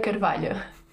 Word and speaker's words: Carvalho: Okay, Margarida, I pronounce your name with my Brazilian accent from João Pessoa, Margarida Carvalho. I think it would be nice for the listeners to hear the pronunciation with your Portuguese accent. – It is Margarida Carvalho.